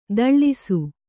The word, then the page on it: ದಳ್ಳಿಸು: kannada